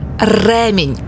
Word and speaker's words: ремінь: Наголос на «е» – ремінь. Ось так правильно.